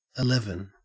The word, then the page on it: eleven: Australian English